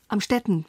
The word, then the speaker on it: Amstetten: Sie kommen aus einem kleinen Ort, aus Amstetten. Amstetten, spricht man.